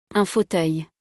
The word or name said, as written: un fauteuil